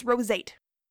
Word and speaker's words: roseate: I wanna say rosette. It's not rosette, it's roseate. R-O-S-E-A-T-E.